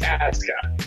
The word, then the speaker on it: Asuka: Asuka? Is that how we're saying it? – WWE is doing one thing right because Japanese pronunciation, you never pronounce the U. Okay, but it's Asuka.